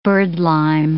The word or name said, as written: birdlime